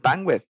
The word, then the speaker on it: bandwidth: Escucho mucho a la banda decir bandwidth. El bandwidth. El bandwidth. Y no, es bandwidth. Ese TH, ese TH, no te puedo decir que es esencial,